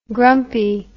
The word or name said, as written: grumpie